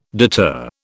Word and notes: deter: British English

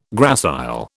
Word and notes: gracile: British English

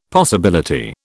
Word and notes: possibility: British English

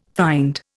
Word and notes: find: Indian

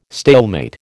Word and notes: stalemate: American English

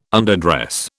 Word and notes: underdress: British English

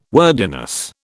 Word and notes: wordiness: British English

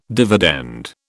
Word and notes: dividend: British English

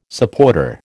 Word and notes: supporter: American English

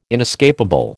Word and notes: inescapable: American English

